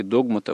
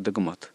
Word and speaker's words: догмат: Оказывается, да, некоторые словари дают два варианта, что может быть и догмат, а может и догмат. И тот и другой вариант уже почти считается равноправным. – Ну, тогда не будем здесь догматиками, да? – Хотя нет, но изначально все-таки правильный догмат было произносить.